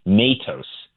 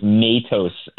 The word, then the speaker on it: Matos: They both told me Matos. Again, I'm open to the idea that there's different ways of saying it. As you said, there might be a Portuguese pronunciation, but we were told by the courts and the governor's office to go with Matos to get it right.